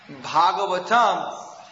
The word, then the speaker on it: Bhagavatam: And Bhagavatam, the long A, that means the song about Krishna, about the Supreme Person.